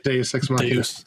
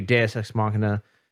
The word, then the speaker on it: Deus Ex Machina: was it like Deus Ex Machina or something? I'm probably saying that wrong. – Yeah, Deus Ex Machina. – Yes, thank you. Deus Ex Machina.